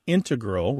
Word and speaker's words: integral: You mainly hear integral with the stress on the first syllable among mathematicians and specialists.